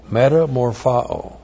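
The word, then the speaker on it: metamorphao (μεταμορφόω): That's M-E-T-A-M-O-R-P-H-O, long O, metamorphao.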